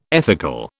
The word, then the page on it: ethical: British English